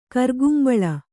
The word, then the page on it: ಕಿರಿಕುಂಬಳ: kirikumbaḷa